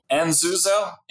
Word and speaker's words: Enzuzo: Who are you and what is, how do you pronounce it? Enzuzo? – Enzuzo, it means privacy.